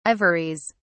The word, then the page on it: ivories: British English